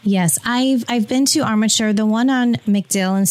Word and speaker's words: Armature: Is it Armature? Is that how you pronounce it? – Yes. – Armature Work.